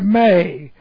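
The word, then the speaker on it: may (μή): First of all, we have the negative used by our Lord is the negative may. It looks like this, M long E sound, may.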